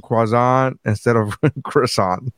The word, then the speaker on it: croissant: I have to pronounce it correctly in the French, croissant.